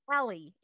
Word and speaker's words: Ehle: But before we do that, I want to introduce you to Holly, and I believe it's Ehle.